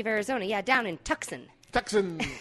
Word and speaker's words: Tucson: than once, you would, you know, that it's pronounced Tucson. Tucson.